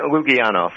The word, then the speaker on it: Lukianoff: Lukianoff.